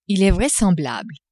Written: il est vraisemblable

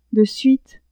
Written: de suite

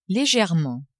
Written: legerement

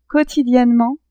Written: quotidiennement

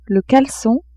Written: calecon

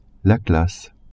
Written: classe

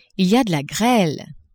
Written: il y a de la grele

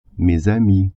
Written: amis

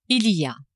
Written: il y a

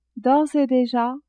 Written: d'ores et deja

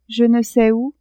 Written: je ne sais ou

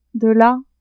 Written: dela

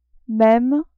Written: meme

